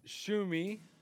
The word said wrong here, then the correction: Tsushima: It's not Shumi, but I can't pronounce it. – Tsushima? – Ghost of Tsushima.